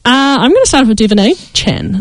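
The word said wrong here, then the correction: De'Von Achane: Devon A. Chen. – Nice. – He would like his name pronounced.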